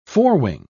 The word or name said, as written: forewing